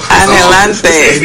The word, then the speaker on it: anhelante: Anhelante. – Maybe we say it different in Cuba, but according to Google, that's how you say it. – Anhelante.